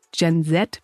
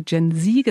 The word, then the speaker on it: Gen Z: Vielleicht ist euch aufgefallen, dass Nils und ich Gen Z bzw. Gen Z gesagt haben, also einmal britisch und einmal amerikanisch ausgesprochen.